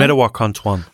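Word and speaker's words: Mdewakanton: Aha, okay, dann hast du jetzt schon mal ein paar First Nations in den Ring geworfen, unter anderem, ich werde es wahrscheinlich nicht korrekt aussprechen, aber ich probiere es einfach mal, die Madawakanton Dakota, ist das richtig so ungefähr? Hilf mir nochmal. – Madawakanton.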